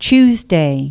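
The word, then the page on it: antradienis: Lithuanian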